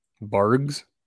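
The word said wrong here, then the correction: Barq's: root beer. – You mean Barks? – Is it Barks? – Apparently it's pronounced Barks.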